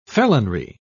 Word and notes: felonry: British English

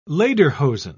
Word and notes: lederhosen: British English

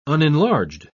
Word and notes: unenlarged: British English

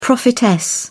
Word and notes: prophetess: British English

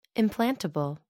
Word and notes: implantable: American English

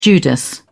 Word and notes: judas: British English

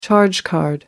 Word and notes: charge card: American English

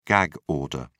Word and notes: gag order: British English

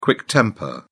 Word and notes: quick temper: British English

